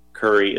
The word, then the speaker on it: Currey: Curry is spelled C-U-R-R-E-Y, and there is a contact form that folks are welcome to fill out.